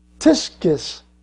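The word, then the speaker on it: tiskis (κτίσις): And it's pronounced something like tiskis. The K is silent. It's tiskis, all right?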